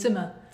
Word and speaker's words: Zimmer: is that how you say it? – Zimmer. – Yeah. Hans Zimmer.